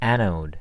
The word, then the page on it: anode: American English